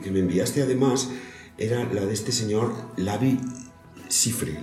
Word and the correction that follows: Livi Sifre: No, ¿cómo se llama el hombre este? – Sí. Libi Libi. Oh, perdóname. Livi Sifre.